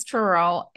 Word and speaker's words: Truro: Yeah, but no, it's Truro, and it's a town that's also in Barnstable County.